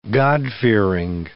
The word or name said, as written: God-fearing